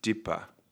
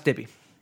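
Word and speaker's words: Dippé: I also want to say, I feel like you probably pronounce his last name Dippa. – No, it's Dippy.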